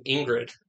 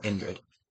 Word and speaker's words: Indrid: Oh, I thought you were like mispronouncing Ingrid. – No, Indrid.